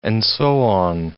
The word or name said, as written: and so on